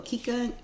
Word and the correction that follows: Kiki: Oh, Kika. I don't even know how to pronounce my own name. Kiki. Kiki and Kiva.